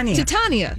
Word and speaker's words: Titania: Well, Jameela Jamil is going to play the villain, reportedly playing a character called Titania, maybe that's how you say it. – Sure. – I'm sorry Marvel. – Titania.